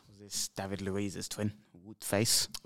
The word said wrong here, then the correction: Wout Faes: Wout Faes, yes. – Is that how you say his name? Wout Faes.